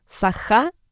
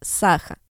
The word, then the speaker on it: Саха: Собственно, да, ударение должно было быть республика Саха, а не республика Саха.